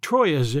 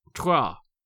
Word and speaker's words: Troyes: This was the Treaty of Troyes, spelled like Troyes in English, but pronounced Troyes.